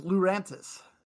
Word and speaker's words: Lurantis: And it's Lurantis.